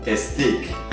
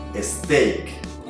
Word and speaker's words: steak: Pero mucha gente la pronuncia stick. No digan stick, digan steak. ¿Cuál es el truco acá? Al final de la palabra, antes de la K, hay una A. Pronúnciala como si fuera una I. De esa manera, fácilmente vas a decir steak.